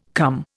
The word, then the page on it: come: Indian